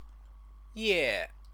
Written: ye

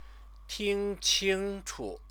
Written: tingqingchu

